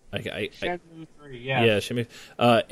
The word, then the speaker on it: Shenmue: Shenmue 3. Am I saying that right? – Shenmue 3.